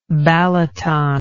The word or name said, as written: balaton